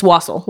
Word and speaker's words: wassail: I don't know, is that how you say it? – It's wassail.